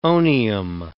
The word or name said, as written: onium